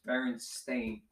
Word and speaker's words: Berenstain: We remember it this way, but no, it's Berenstain. – Apparently. – Like stain on your carpet.